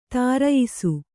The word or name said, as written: ಧರಿಯಿಸು